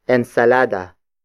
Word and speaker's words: ensalada: It is the direct object marker, indicating that the next word is what you should eat, and that is ensalada. Ensalada. Ensalada. Ensalada is spelled as E-N-S-A-L-A-D-A.